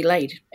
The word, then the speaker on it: Layd: My dad calls me Laid, and so we spell it that way, L-A-Y-D.